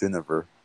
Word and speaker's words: Guinevere: Also, I think it's Guinevere. That's how you pronounce her name. – Now, her skill set? Guinevere? I feel like I'm going to solve. Guinevere. Okay. Guinevere. – Like Jennifer, but with a V.